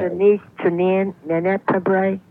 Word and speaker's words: Fabares: She spells it F-A-B-A-R-E-S. Does she pronounce it Fabre? – Yeah. – She does? – Yep. Shelley Fabre, yep.